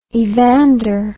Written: evander

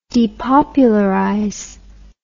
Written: depopularize

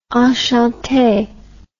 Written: enchante

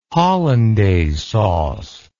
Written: hollandaise sauce

